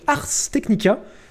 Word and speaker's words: Ars Technica: Donc Ars Technica, euh, ARS plus loin Technica, comme ça se prononce.